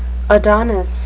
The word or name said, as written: adonis